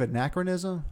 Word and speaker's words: anachronism: Creative anachronism? – Anachronism. – Okay. – Is that how you say it? – Yes.